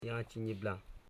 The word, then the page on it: jachinyibla: ja.chi.nyi.bla